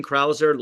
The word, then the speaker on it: Krauser: That's Vote Shane Krauser. Last name is spelled K-R-A-U-S-E-R.